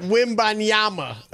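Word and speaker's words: Wembanyama: What's longer, the Riverwalk or Wimbiani? – You got to learn how to say it, Wimbanyama.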